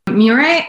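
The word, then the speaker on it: moiré: Say a bit about Miore. How do you spell it and what is it? – Miore, it's a M-O-I-R apostrophe E.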